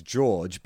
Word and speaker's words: Jorge: It's spelled J-O-R-G-E, which instinctively I'd say is George, but has it got like a Spanish twist where it's like Jorge or Jorge? – Well, I would say Jorge would be that.